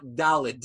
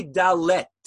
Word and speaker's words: dalet: The Hebrew letter dalid is really, I'm not pronouncing it correctly. It's not dalid, D-A-L-E-D. That's really not the correct pronunciation. It's really dalet with a T at the end.